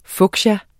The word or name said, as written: fuchsia